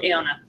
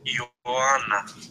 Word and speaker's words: Iona: Iona. – Did you hear that? – Iodonus. – Iona. – Oh, so it's like Joanna, kind of. Interesting. – Yeah, and it's Greek, so.